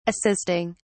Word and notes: assisting: British English

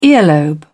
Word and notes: earlobe: British English

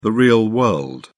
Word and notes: real world: British English

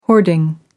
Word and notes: hoarding: American English

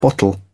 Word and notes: bottle: British English

